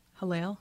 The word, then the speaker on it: Hallel: I, I googled um, Hallel. Am I saying that right? – Yes.